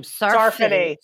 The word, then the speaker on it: Sarfati: Sarfati. Yeah. Like Sephardic Jew.